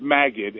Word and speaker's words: Magid: I think it's just pronounced Magid. Magid. M-A-G-I-D, if I'm not.